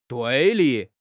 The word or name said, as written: 对立